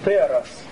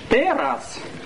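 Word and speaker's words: Терас (τέρας): Терас. Это чудо. Терас. Читается по-русски, как вы видите.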